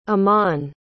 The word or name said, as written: aman